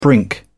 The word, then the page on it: brink: British English